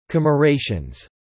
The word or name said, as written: commerations